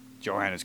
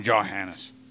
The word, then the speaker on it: Johannes: Source 1 recognized that the Arabs and the Greek humans knew about the camera obscura way before Johannes Kepler's time. You know they call him Johann? What is that? He's German. The J was not pronounced that way. Mm-hmm. I still call him Johannes.